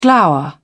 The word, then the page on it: glower: British English